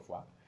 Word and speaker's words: Foix: Como les digo, este es el condado de Foix, el famoso condado de Foix, no de Foie Gras, porque se escribe F-O-I-X, ¿no? El otro Foix. Foix, en realidad.